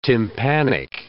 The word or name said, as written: tympanic